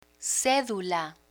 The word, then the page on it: cedula: spanish